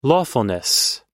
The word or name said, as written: lawfulness